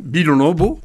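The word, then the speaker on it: Vilanòva: Ils ont fondé, en occitan, Bilonobo, Villa Nova, parce que il faut le prononcer en occitan.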